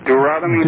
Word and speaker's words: Deuteronomy: Deuteronomy.